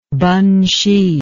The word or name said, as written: Penhsi